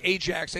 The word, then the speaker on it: Ajax: It looks like Ajax. They pronounce it Ajax in Holland.